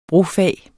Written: brofag

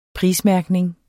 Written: prismærkning

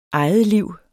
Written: egetliv

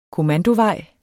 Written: kommandovejen

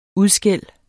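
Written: UDSKÆLD

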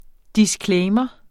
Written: disclaimer

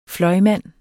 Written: fløjmand

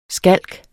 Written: skalk

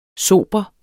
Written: sober

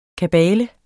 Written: kabale

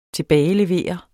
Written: TILBAGELEVER

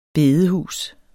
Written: bedehus

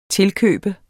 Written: tilkøbe

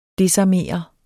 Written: desarmere

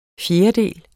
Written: fjerdedel

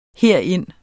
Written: herind

